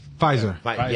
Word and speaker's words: Pfizer: I don't know if I'm pronouncing this right, but Pfizer? – Pfizer. – Yes. – The P is Puerto Rican.